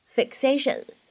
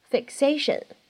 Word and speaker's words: fixation: 首先呢，fixation 当中呢存在一个不完全爆破，所以呢我们会读成 fixation。 fixation，你会感觉这个爆破音，它好像呢只做了口型，没有完全读出来。 fixation。